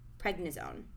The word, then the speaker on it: prednisone: prednisone. Is that how you say it? – Yep. Yeah, steroid.